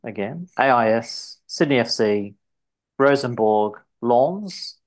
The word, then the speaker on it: Lens: He went off to, how do I pronounce this in French? Lens. Or if I were to just pronounce it phonetically, Lens. But Lens, French.